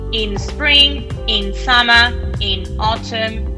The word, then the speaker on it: autumn: No, cuidado con autumn porque. Pasa igual que con August, ¿vale? Se escribe A-U-T-U-M-N, ¿vale? Autumn, pero no hay ninguna A ni ninguna U, ¿vale? Es autumn.